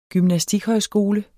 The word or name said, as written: gymnastikhøjskole